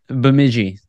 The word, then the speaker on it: Bemidji: Flyers made a recent signing of Wills Moleck, a 6'4", 210-pound defenseman, right-handed defenseman from Bemidji State. I think I'm saying that right. – Sure. – Bemidji. Thank you.